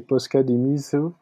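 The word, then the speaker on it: Posuka Demizu: de nous dire comment on prononce bien les, les, les noms de ces deux auteurs. – non, pas trop. Je lis mieux que, que je parle, mais euh, normalement c'est Posca Demiso et euh, Kaiu Shirai.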